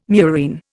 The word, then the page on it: murine: Indian